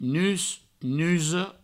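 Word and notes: nus, nusa: nus, nuso

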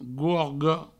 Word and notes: gorga: gorgo

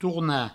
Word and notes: tornar: tourna

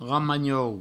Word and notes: ramanhou: provençal; ramagnou

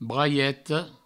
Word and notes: braieta: braieto

